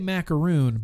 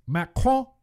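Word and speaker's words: macaron: Okay, so I say macaroon, but it's not macaroon, right, Joshua? It's Macron. French pronunciation.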